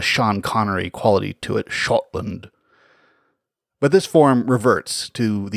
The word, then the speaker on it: Scotland: Another interesting fact is that the Old English form of Scot indicates that it would have been pronounced like other Old English SC words, that is, with an SH sound. Shot or Shiota, Shotland.